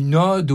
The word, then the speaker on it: ode: Ils ne savent pas prononcer, comme des millions de gens actuellement, le mot une ode au DE, ça se dit avec un O ouvert et pas une ode, comme on entend dans le film, comme le prénom, avec, comme si c'était un O fermé.